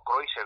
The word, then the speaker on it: Kreutzer: que ya hemos hablado algo con su sonata Kreutzer o Kreutzer, como se pronuncia en alemán,